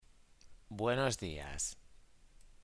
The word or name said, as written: buenos dias